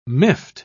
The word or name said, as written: miffed